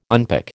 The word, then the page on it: unpick: American English